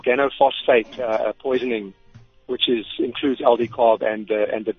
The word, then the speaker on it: Aldicarb: And Aldicarb. – It's very easy to obtain. Aldicarb. Yes. – How do you say it? – Aldicarb. A-L-D-I-C-A-R-B.